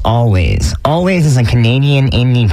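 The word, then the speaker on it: Alvvays: It's spelled with two V's, but it is still pronounced Always.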